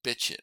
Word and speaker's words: Bichette: He pronounces it Bichette, I think.